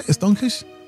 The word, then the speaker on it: Stonehenge: Pero también, Horacio, antes de hacerte la palabra, muchos, o sea, la mayoría aparecen en cerca de Stonehenge. ¿Cómo se dice? Stonehenge.